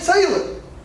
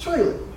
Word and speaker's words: Traylor: K-O-H. I'm like, my name wow. – is K-O-H. And it ain't Taylor. It's Traylor.